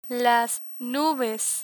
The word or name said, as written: nubes